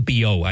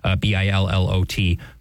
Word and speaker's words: Billot: Cardinal Biot, I think, is how you would pronounce it in French. Billot, who helped draft encyclical Pascendi Dominici Gregis.